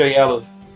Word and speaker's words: Oyelowo: Do Olioko, alguma coisa assim. É David Olioko, que também é um bom ator. – É David Oyelowo.